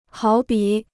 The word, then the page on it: 好比: hǎo bǐ